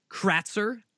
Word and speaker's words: Kratzer: Chris Kratzer, K-R-A-T-Z-E-R, the way it's spelled, very easy.